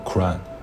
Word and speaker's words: Quran: 是音译过来的，英文发音叫 Quran，它也可以被翻译为《可兰经》。《古兰经》是伊斯兰教唯一的根本经典。